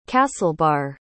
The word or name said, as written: castlebar